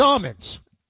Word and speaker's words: almonds: It's almonds. – Almonds? There's an L in almonds. – It's a silent L.